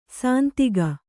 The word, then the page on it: ಸಂದೇಗ: sandēga